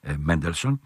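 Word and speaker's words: Mendelssohn: τον Εβραίο φιλόσοφο Μόζες, όπως προφέρεται από τους Σάξονες, Μέντελσον.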